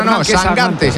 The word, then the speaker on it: Sangante: Sangante, se pronuncia Sangante. – Yo pronuncio como me digáis. – Bueno, eso lo he oído yo, que dice que es Sangante. – De hecho, me ha dicho, tú acuérdate de Mangante.